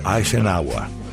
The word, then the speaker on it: Eisenhower: Se pronuncia Eisenhower.